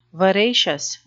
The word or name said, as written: veracious